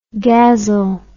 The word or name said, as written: ghazal